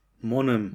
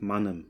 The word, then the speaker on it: Mannheim: die einen sagen Monem und auf der einen Hälfte sagt man Monem, die anderen sagt Mannem, aber Monem kenne ich das.